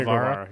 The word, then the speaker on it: Guevara: Am I saying that right? How do you say his name? – It's Sammy Guevara.